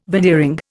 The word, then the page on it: veneering: Indian